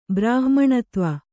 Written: ಬ್ರಾಹ್ಮಣತ್ವ

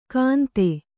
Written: ಗಂದೆ

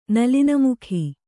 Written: ನಲಿನಮುಖಿ

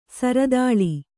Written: ಸಿರತಾಳಿ

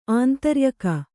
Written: ಆಂತರ್ಯಕ

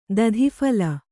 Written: ದಧಿಫಲ

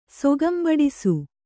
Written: ಸೊಗಂಬಡಿಸು

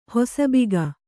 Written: ಹೊಸಬಿಗ

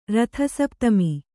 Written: ರಥಸಪ್ತಮಿ

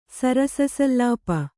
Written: ಸರಸಸಲ್ಲಾಪ

